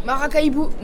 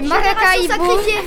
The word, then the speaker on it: Maracaibo: Alors, vous avez choisi un film, alors vous prononcerez peut-être mieux le nom que moi, c'est quoi ? Maca, Macara ? – Maracaibo. – Maracaibo. Maracaibo, génération discrète, secrète, sacrifiée. – Non. C'est Maracaibo.